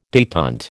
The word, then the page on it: detent: American English